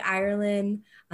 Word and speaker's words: Irelan: Follow me on social media, Rae Irelan. I'm sure my name is spelled somewhere around here. And no D, no D. – Just like the country, but no D.